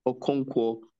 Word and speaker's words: Okonkwo: Okonkwo, Okonkwo. I say that again. Okonkwo.